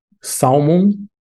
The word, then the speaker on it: salmon: Salmon. – Mm-hmm. This word, it's common to want to pronounce the L. But actually, salmon. – Salmon. – The L is silent.